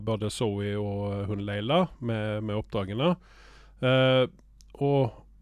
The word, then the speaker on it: Laysla: Det stammer, staves ikke Leila, men jeg uttaler det som Leila, for det staves L-A-Y-S-L-A. Jeg vet ikke hvordan disse meksikanerne staver sånne ting, men vi sier Leila.